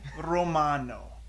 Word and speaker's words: Romano: My name is Daniel, and this is my friend Andy Romano. How do you say your last name? – Just Romano. Oh, okay. No need to roll the tongue.